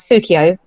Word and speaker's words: Keogh: But my my husband has got this Irish surname, which okay. And I live in the east of England, and people just can't pronounce it. So when we got married, I used to write it down as Sukio, S-O-O-K-I-O, to say, oh, this is how you pronounce it.